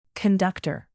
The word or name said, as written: conductor